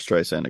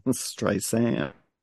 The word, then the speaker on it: Streisand: I don't see that movie getting made because I don't see Barbara Streisand agreeing to that at that time. – Because John Peters was busy teaching people how to say her name. – Streisand.